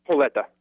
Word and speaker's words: Holeta: The Ethiopian Covenant Church is it pronounced just like it looks, Holita? – Holeta.